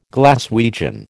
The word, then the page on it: Glaswegian: American English